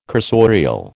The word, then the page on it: cursorial: American English